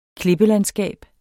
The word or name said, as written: klippelandskab